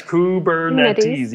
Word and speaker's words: Kubernetes: Come on, you write on me like Kubernetes. – I know how to say it. Yes, Kubernetes.